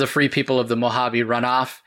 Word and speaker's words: Mojave: And at this point, Robot Paul, with relief, says, finally, and goes on to tell Mr. Braille, it's pronounced Mojave. H, there's an H sound.